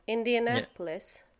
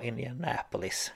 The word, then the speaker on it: Indianapolis: Indianapolis. Ja, Indianapolis.